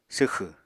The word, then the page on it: swxẁ: apatani